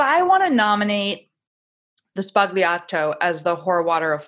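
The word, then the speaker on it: Sbagliato: And just to let you know, the G is silent. It's Spagliato. It makes a wild sound.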